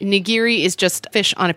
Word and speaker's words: nigiri: So nigiri.